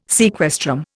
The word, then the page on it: sequestrum: Indian